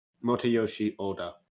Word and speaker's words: Motoyoshi Oda: It was directed by Motoroshi Oda, and the movie. – I'm pretty sure you mispronounced his name, but oh well. – Okay, how would you pronounce it? – Motoyoshi Oda.